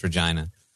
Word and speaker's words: Regina: He said Regina? – Regina. The chat corrected me. It's Regina.